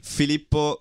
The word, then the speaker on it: Filippo: Filippo Grondona.